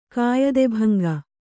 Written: ಕಾಯದೆಭಂಗ

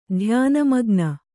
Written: ಧ್ಯಾನಮಗ್ನೆ

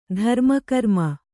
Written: ಧರ್ಮಕರ್ಮ

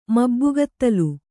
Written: ಮಬ್ಬುಗತ್ತಲು

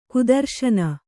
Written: ಕುದರ್ಶನ

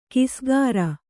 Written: ಕಿಸ್ಕಾರ

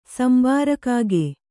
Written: ಸಂಬಾರಕಾಗೆ

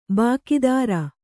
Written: ಭಾಗೇದಾರ

